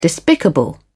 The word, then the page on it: despicable: British English